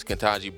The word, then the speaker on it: Ketanji: Ketanji Brown Jackson. My apologies. Ketanji Brown Jackson.